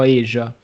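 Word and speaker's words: Aja: David Aya, giusto? Ah, e... Eh, allora, eh, ci sono dispute in dottrina, qualcuno potrebbe pronunciarlo Asia.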